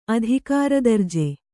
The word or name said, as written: ಅಧಿಕಾರದರ್ಜೆ